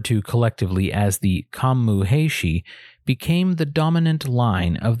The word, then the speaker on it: Heishi: Taira itself is a single kanji, so the phrase Taira clan is a compound of two kanjis pronounced as Heishi, and the phrase Taira house is thus pronounced Heike.